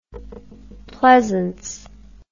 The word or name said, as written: pleasence